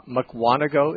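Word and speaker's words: Mukwonago: Okay, so just before you're gone, and so I can get this through my head, McWanago is how you pronounce it? – That's how everybody around here does. – Okay, well, I'm going to trust you on that. So the accent's on the second syllable. Okay, I'll try to keep that in mind in the future. – Four syllables. Just think four syllables.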